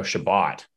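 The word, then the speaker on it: Chabot: You know, they've only really got like, is it Shabbat? Does he pronounce it Shabbat? – Thomas Shabbat. – Oh, Shabbat.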